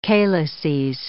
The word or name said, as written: calices